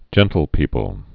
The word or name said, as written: gentlepeople